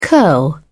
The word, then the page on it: curl: British English